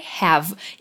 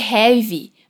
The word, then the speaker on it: have: Olha só, a gente viu que para dizer eu tenho em inglês é I have. E cuida com a pronúncia aqui. Não é I have, mas have. Termina com esse V mudo, sem o E no final. Have.